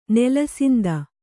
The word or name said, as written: ನೆಲಸಿಂದ